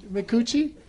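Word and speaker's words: Micucci: Did I pronounce that right, McCucci? – McCucci.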